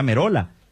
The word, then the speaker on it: Merola: No, y porque en italiano se pronuncia Merola.